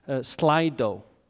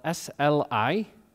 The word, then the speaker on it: Slido: I'll ask him the questions, and you will put them online on a site called Slido. So it's spelled SLI.DO, Slido. I'll say it in Icelandic as well, SLI.DO, Slido.